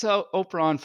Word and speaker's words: Operon: So Operon has the same Latin root as opera and operator, so however you pronounce those words, it's just opera and then on.